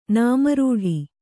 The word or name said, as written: ನಾಮರೂಢಿ